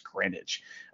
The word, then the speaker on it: Greenwich: It's spelled Greenwich, but I know it's pronounced Greenwich.